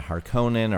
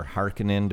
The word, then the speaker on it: Harkonnen: And we get this glimpse of the Atreides family and the Harkonnen or Harkonnen, depending on which version you believe is the correct pronunciation, and their conflict over Arrakis.